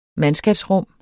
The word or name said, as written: mandskabsrum